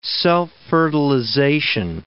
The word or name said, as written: self-fertilization